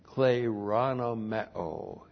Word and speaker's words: kleronomeo: So having become so much superior than all the angels, by so much as he has kleronomeo, he has inherited something. Kleronomeo. Looks like that in the Greek, K-L, long E-R-O-N-O-M-E, long O, kleronomeo.